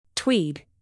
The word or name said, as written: tweed